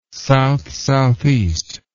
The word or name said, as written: south-southeast